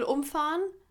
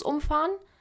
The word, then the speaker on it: umfahren: Also, wenn man halt umfahren sagt, das den Dingens Betonung auf um, dann ist es halt wirklich dagegen fahren und einfach so boom, einmal volle Kanne durch, wie Kassi sagt.